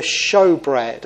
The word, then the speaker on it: Shewbread: And we see it in something called the Shewbread, and that's how you pronounce that.